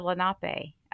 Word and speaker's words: Lenape: I said, how do you say Delaware? And he said, Lenape. I said, you're right.